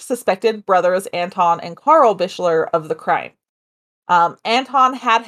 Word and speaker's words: Bichler: I'm sorry, the what brothers? – Bichler? B-I-C-H-L-E-R. – I really hope that's how you say it. – I hope so, too. Maybe it's Bichler? – Bichler. You're being a real Bichler.